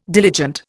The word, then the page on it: diligent: Indian